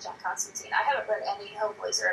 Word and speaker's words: Constantine: Which, during the series, he says that his name is pronounced Constantine.